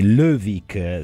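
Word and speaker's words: Løvik: Sì, sto parlando di Lovic, se non sbaglio, quella tagliata, la si pronuncia così,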